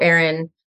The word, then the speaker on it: Erynne: So, I mean, I guess the easiest way would be through my own Instagram, which is XO Erynne, like a signature.